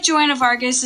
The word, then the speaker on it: Joanna Vargas: Joanna Vargas. Or is that, am I saying it right? – Yes. – I feel like Joanna Vargas is someone I went to high school with.